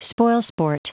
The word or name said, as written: spoilsport